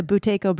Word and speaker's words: Buteyko: Buteyko breathing? – Buteyko.